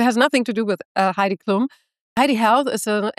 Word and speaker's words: Heidi: I pronounce it Heidi, but I quite like the way that you say it, to be honest.